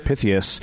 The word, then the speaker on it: Pythias: There's some of them that are no longer around, but then we get to see stuff like the Knights of Pythias. – Did I say that right? Yeah, they've been around for a long time, and there's literally, like, a giant meeting hall inside of a building that is just...